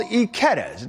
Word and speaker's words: Ikeda's: It's called Ikeda's, and it's pronounced exactly like the name of our colleague and friend, Emily Ikeda.